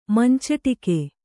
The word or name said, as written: ಮಂಚಟಿಕೆ